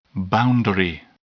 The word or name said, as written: boundary